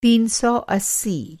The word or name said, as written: तीन सौ अस्सी